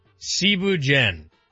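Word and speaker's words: thieboudienne: First off, Cebu Dien. It's spelt like Thibu Dien.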